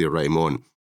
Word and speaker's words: Raimon: Is that pronounced okay? – It's perfectly pronounced, Raimon.